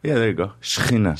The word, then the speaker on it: Shekinah: And I believe, I bet you'll know how to say it, Shekinah? – The Shekinah.